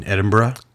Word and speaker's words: Edinburgh: So, listeners, um, in Edinburgh. Oh, now we can say it properly. Edinburgh, La Llorona.